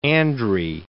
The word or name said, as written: Andre